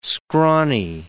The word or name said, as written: scrawny